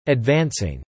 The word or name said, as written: advancing